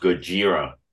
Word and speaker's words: Gojira: it's pronounced Gojira, you moron.